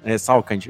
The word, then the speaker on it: Salkind: O filme, só falando aqui sobre os produtores executivos que o nosso querido André citou, tava correta a pronúncia, Salkind, não tá tudo certo,